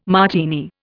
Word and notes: Martini: Indian

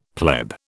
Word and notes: pleb: British English

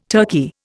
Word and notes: turkey: Indian

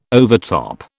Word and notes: overtop: British English

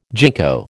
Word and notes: gingko: American English

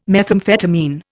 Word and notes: methamphetamine: Indian

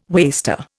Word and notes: waster: Indian

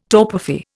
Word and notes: torpefy: Indian

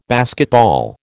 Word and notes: basketball: American English

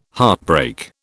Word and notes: heartbreak: British English

